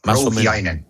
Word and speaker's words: Rouhiainen: Lasse, ¿he pronunciado bien tu apellido? – Más o menos, Rouhiainen, pero muy cerca, ¿eh? – Rouhiainen, ahí estoy.